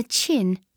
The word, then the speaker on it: Qin: The first of the Great Walls in the north of China was built in the Qin, pronounced Qin, Dynasty by the Emperor Shi Huangdi, pronounced Shi Huangdi, in about 200 BC.